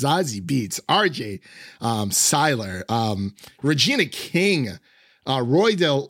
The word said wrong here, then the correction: Delroy: Uh, uh Delroy Lindo, that did I did I butcher his name? is it Delroy? Yeah. It is okay. I almost said Roy Del Lindo. I'm like, that is not correct.